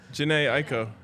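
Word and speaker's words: Jhené Aiko: Now I'm getting texts wondering how I messed up Janae Aiko or whatever the... how did you say it? – Janae Aiko, yeah.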